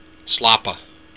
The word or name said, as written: slapa